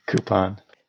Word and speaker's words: coupon: Coupon. – Which I think is the correct way, by the way, right? There's no Q in coupon.